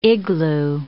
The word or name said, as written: igloo